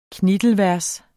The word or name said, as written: knittelvers